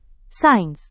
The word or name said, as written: sains